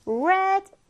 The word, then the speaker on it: read: 그런데 발음이 좀 다릅니다. 방금 쌤이 어떻게 발음했어요? read, red, red.